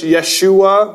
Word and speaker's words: Yeshua: Yeshua would be the Hebrew pronunciation.